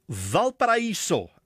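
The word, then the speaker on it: Valparaíso: Op die lyn het ek vanuit Valparaiso in Chili. Middag. – Middag, jou uitspraak is perfek van hierdie stad wat jy so moeilik uitspreek. Joune is perfek.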